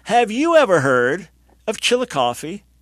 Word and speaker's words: Chillicothe: And we go in Chicopee, Ohio. Is that the pronunciation? – No, sir. It's Chillicothe.